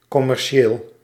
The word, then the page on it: commercieel: /ko.mɛɾ.sje:l/